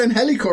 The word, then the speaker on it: Angelico: That's Angelico. – Hey, you got Angelico right.